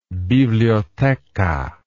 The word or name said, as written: biblioteca